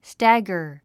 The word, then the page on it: stagger: stǽɡər; スタッガァ